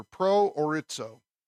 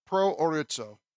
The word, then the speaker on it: proorizo: Pro orizo is what it is. Pro orizo. In fact, that's not right. I accidentally typed the P instead of the O. It should be P-R-O-O-R-I-Z-O, long O at the end. So P-R-O-O-R-I-Z-O.